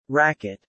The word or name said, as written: racket